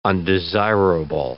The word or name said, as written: undesirable